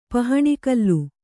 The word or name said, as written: ಪಹಣಿಕಲ್ಲು